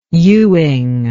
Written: ewing

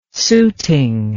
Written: suiting

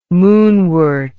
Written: moonwort

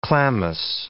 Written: chlamys

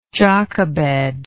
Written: jochebed

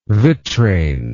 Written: vitrain

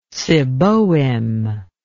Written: seboim

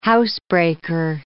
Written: housebreaker

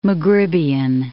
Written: Maghribian

